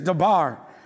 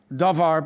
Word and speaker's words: davar: The Hebrew word for matter is dabar, or it's actually spelled davar, but you pronounce it dabar.